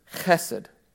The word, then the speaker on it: chesed: Kids, this is in Hebrew a word pronounced chesed. You know you're saying it right when you spit, okay?